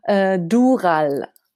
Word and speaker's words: Dural: Dural.